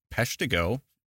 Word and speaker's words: Peshtigo: I'd like to introduce everyone to Wendy from the, I think it's Peshtigo Fire Museum. Is that correct? – Yes, it is.